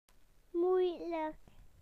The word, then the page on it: difficult: British English